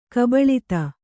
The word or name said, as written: ಕಬಲಿತ